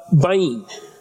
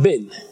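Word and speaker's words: bin (בין): And the first one is bain, or pronounced bin, right? B-I-Y-N.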